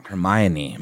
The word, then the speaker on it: Hermione: Hermione, my man, Hermione.